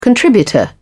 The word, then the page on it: contributor: British English